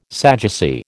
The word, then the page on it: Sadducee: American English